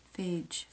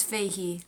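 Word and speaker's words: Fage: It's Fahey. Nope, the Greek yogurt brand does not rhyme with Phage.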